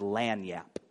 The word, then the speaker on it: lagniappe: It's a French Creole word, and the word is lagniappe.